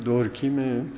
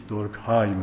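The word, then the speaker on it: Durkheim: دورکیم فرانسوی است. تلفظ انگلیسیش دورکهایم میگن، بعد کار به جایی رسید که گفت نه اصلا وجود جامعه یک وجود مورد تقدیسه.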